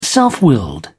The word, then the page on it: self-willed: British English